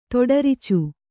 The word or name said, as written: ತೊಡರ್ಚು